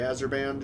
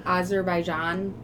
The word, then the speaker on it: Azerbaijan: Azerbaijan. I'm sorry, I'm butchering this. – I think you got it the first time. – Okay. – Or Azerbaijan. – Yeah, that sounds good.